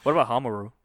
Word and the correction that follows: Homura: Homaru? Homura? I'm sorry, man. I butchered Homura? – Name. There you go.